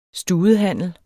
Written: studehandel

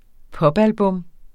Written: popalbum

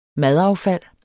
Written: madaffald

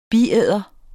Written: biæder

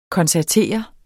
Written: koncertere

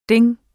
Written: ding

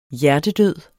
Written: hjertedød

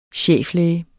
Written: cheflæge

